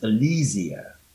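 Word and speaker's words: Alleezia: Her name is Alizia, A-L-L-E-E-Z-I-A. I mean, that's how it came out. That's phonetically how it sounds anyway. So we don't call her Barry anymore. It's Alizia.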